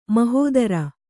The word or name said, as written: ಮಹೀಧ್ರ